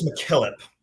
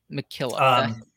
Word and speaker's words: MacKillop: Am I pronouncing that right? – MacKillop? I believe it's MacKillop. – MacKillop. – yeah, she's a Scottish in root, and it's usually it's Mac is how it goes.